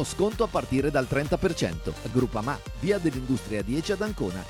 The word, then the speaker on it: Groupama: DJ Biancorosso, Groupama, si pronuncia Groupama, di Paolo Felicetti.